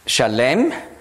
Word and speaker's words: Salem: Und er übersetzte auch gleich das Wort Salem, Shalem, hebräisch ausgesprochen, das ist Frieden, das ist verwandt mit Shalom.